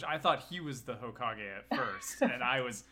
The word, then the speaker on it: Hokage: Hokage.